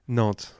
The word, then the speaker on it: Nantes: Nantes, which is just down south Brittany. Okay. So on the west side of France. – N-A-N-T-E-S.